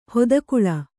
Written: ಹಿತಗೊಳ್

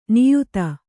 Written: ನಿಯತಿ

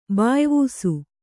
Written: ಬಾಯ್ವೂಸು

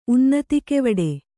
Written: ಉನ್ನತಿಕೆವಡೆ

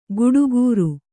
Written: ಗುಡುಗೂರು

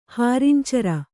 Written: ಹಾರಿಂಚರ

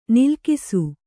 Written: ನಲುಗಿಸು

